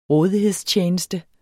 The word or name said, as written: rådighedstjeneste